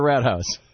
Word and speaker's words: Rathaus: It's Rathaus. R-A-T-H-A-U-S. It's actually pronounced. – That. – It's actually pronounced Rathaus, and it means government building.